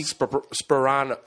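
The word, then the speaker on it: Sparano: It's Tony Sperano. Sperano. – Yeah, Sperano. Tony Sperano. – The P's in the beginning. – Oh, okay. – Yeah, it's not Soprano.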